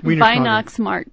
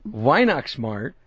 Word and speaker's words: Weihnachtsmarkt: Okay, so is this event, pronounce this again, Weihnachtsmarkt, how do you pronounce that? – Weihnachtsmarkt. – Weihnachtsmarkt.